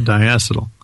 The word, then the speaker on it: diacetyl: I pronounce it diacetyl.